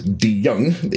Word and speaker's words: DeJong: My real last name is DeJong. It's spelled D-E-J-O-N-G.